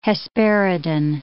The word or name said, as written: hesperidin